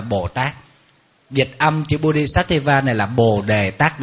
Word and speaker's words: Bodhisattva: Bồ Tát tiếng Phạn đọc là Bodhisattva.